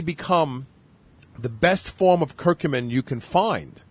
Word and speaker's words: curcumin: And I found a company that is now manufacturing a form of curcumin. Am I pronouncing it right, Peter? Is it curcumin? – Curcumin.